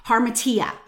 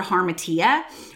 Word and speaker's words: hamartia: And I usually butcher it, but, let me just spell it. H-A-M-A-R-T-I-A, harmatia, I think is how you say it.